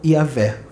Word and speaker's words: Javé: porque se escreve Javé, tem uns que falam Yavé, ou Jeová.